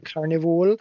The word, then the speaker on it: Carnivool: Am I pronouncing that wrong? – Yep. No, you said it right. – Carnivool.